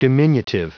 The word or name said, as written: diminutive